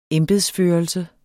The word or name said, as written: embedsførelse